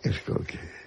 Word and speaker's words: Skolsky: No, señor, no es SH. Es SKOLSKY. – Impronunciable. – A la señora... – ¿Cómo se pronuncia? – Porque es polaco. ¿Cómo se pronunciaría en polaco Skolsky? – Skolsky. Como tú lo has dicho.